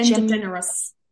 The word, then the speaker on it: DeGeneres: Yeah, so we're going for Ellen DeGeneres. I can't say her name. – Ellen DeGeneres. – DeGeneres.